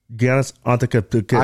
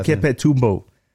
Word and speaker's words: Antetokounmpo: Giannis Antetokounmpo. – I can't pronounce that. – Antetokounmpo.